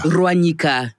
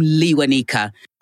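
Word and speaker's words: Lewanika: That's the real pronunciation, but the British don't know how to do the Ruanyi. So they just said, okay, let's just call him Liwanika.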